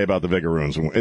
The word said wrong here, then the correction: vinegaroon: Is that how you pronounce it, Chris? How do you say it? – Vinegaroon. – Vinegaroon. Like if it were shooting ketchup instead of vinegar, it'd be ketchup-a-roon.